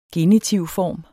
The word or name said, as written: genitivform